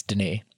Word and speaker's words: Denis: Ray Harrington was the one who was like, it's not Dennis, it's Denis.